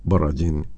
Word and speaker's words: Borodin: De Piotr Ilich Tchaikovsky se ha escuchado Cuarteto de cuerdas en si bemol mayor por el Cuarteto de cuerdas Borodin o Borodin en su pronunciación original.